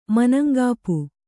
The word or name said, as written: ಮನಂಗಾಪು